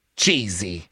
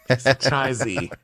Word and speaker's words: Chizi: So I think she just kept bungling this name, and they're like, Lisa, you can't keep calling him Cheesy because his name is spelled C-H-I-Z-I. And they're like, Lisa, practice. All right, do I have it down? Someone write it on the young girl's forehead. Grace, get over here. Cheesy. No, Chizzy.